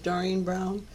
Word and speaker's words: Doreen Brown: My name is Doreen Brown, D-O-R-E-E-N, B-R-O-W-N.